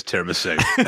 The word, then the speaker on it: tiramisu: Which phonetically is tiramisu. The T is not silent. None of the letters are.